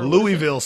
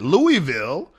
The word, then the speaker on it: Louisville: Louisville. I can say that all day, but that's not the way they say it. They say it in like one long syllable, like Louisville.